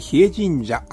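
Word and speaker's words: 日枝神社: 釧路市朝日町に比叡神社、毎日の日に枝と書いて比叡神社、あることご存知でしょうか。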